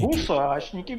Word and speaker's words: гусачники: Или, правильно ли я поставил ударение? Может быть, гусачники? – Гусачники. Гусачники, гусачники. Гусачники, да, все правильно. Верно.